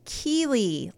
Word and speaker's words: Chele: Chele is pronounced Keely, like the character from Ted Lasso, whose storyline no one likes anymore.